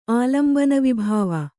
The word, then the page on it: ಆಲಂಬನವಿಭಾವ: Kannada